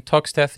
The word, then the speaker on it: Toxteth: born in Toxteth, Liverpool.